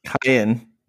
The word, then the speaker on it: Kyan: During the night, at some point, how do you pronounce Kyan? Kyan? Kyan? – Kyan. Kyan. – Kyan. – Like a kayak, but without the ack at the end.